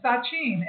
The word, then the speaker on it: Sachin: Sachin. S-A-C-H-I-N. Sachin. It's, how do you spell that again? – S-A-C-H-I-N.